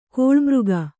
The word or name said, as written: ಕಿಳ್ಮೊಗ